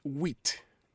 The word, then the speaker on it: huit: 7 is set, S-E-P-T, S-E-P-T, set. The P is silent. Everybody say set. And 8 is wit, wit, H-U-I-T, wit. Everybody, tout le monde, répète après moi, wit. Wit, H-U-I-T, wit.